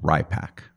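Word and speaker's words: RIPPAC: Pronounced RIPAC.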